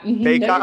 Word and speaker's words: Bacot: What I'm curious about with those four guys, De'Ron Sharp, Walker Kessler, Garrison Brooks, Armando Bacot, I don't know how to say their names. Bacot. – Bacot, all right.